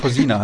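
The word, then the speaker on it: Kozina: Und da bin ich mir nicht sicher, wie es jetzt mit dem Krešimir Kočina, wird der so ausgesprochen, Cousina heißt er, ja, genau. Krešimir Cousina.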